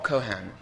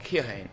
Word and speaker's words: Keohane: I actually only learnt just now that you don't pronounce the E. I've always said Bob Keohane or Cohane. And fortunately, in the emails, and he doesn't know that I didn't know you didn't pronounce the E, but it's Bob Cohan.